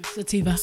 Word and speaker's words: Sativa: Yeah, Sativa.